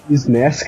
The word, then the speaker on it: SNES: SN, como é que pronuncia? – SNES, cara. – Obrigado. Com isso, peguei a era SNES até o PS2, nesse esquema de aluguel por horas.